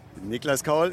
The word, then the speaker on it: Niklas Kaul: Niklas Kaul ist die korrekte Aussprache des Mainzers.